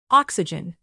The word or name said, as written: oxygen